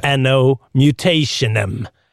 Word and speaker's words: Anno Mutationem: Ett annat nytt spel var det här som heter Annu Mutationem. Hur man nu ska uttala det. Just det. Anno Mutationem.